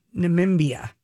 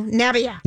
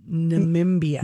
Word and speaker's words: Namibia: Namibia. – No, Nabia. – No, she got it right. – I got it right. – Nabia? – Namibia. – Namibia.